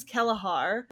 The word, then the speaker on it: Kelahar: There's a lot of C names in this novel, and I believe that the C is pronounced with a hard K sound. So the witness is Kelahar.